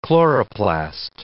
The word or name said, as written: chloroplast